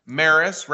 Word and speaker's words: Maris: The Launching Life's Journeys podcast is going to be in partner on a special camping series with MARVAC, starring Maris, rhymes with Paris.